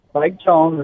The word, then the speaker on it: Spike Jonze: Vamos falar do Spike Jonze. Eu pronunciei certo? – Spike Jonze, exatamente.